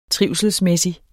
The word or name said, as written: trivselsmæssig